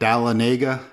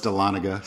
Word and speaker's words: Dahlonega: Most people are like Dahlonega, and it's no, it's Dahlonega.